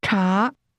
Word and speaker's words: 茶: the word T is pronounced with a rising tone. Say again, T. – Ta.